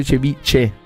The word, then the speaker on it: ceviche: C'è la H. Però in spagnolo si legge cevice. La H in spagnolo rende la...